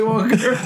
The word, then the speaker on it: Wonka: With Willy Wonka in it. – Yeah. – And stop pronouncing the R in Wonka.